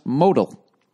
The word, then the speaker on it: modal: It's pronounced modal.